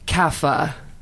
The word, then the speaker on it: Kapha: Kapha.